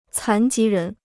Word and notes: 残疾人: cán jí rén

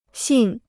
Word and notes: 信: xìn

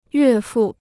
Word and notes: 岳父: yuè fù

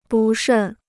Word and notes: 不慎: bù shèn

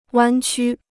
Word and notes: 弯曲: wān qū

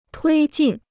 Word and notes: 推进: tuī jìn

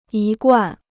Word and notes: 一贯: yī guàn

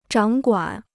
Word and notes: 掌管: zhǎng guǎn

